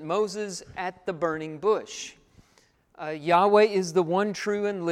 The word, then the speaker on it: Yahweh: Jehovah is not really the proper way to say that Hebrew word. Yahweh is closer to it, the best guess that we have at it.